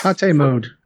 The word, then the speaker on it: tate: and it's pronounced Tate, not Tate. Thank you. It's Tate mode. – It's Japanese for vertical.